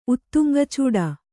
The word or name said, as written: ಉತ್ತುಂಗಚೂಡ